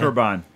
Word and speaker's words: Qurban: Kurban.